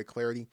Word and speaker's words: Clardy: You can follow me on Twitter at Troy Clarity, at Troy Clarity. Last name is spelled C-L-A-R-D-Y, at Troy Clarity.